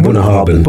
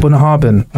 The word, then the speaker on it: Bunnahabhain: Okay, and this is the the Bun, how do I say it? Bunnahabhain. Bunnahabhain.